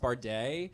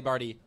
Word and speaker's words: Bardey: Yeah, because my last name is pronounced Barday. – Yes. But, then, uh. but it's spelled B-A-R-D-E-Y, and so I in my head I'm like Charlie Barty. No.